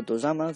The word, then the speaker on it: tozama: En general, aunque la gente tiende a pensar lo contrario, los Tozama, o Tozama, se pronuncia en japonés, eran los que tenían dominios mayores casi siempre.